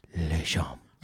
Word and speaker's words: Lashomb: So how would that, the real, real, real pronunciation of your name? – Lashomb. – Lashomb. – Lashomb. – Lashomb. – You could see, but you have to say it exactly like that in Montreal.